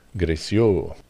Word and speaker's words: Greccio: I have to ask how to pronounce that right. G-R-E-C-C-I-O. Grecio. We were saying more or less in Spanish.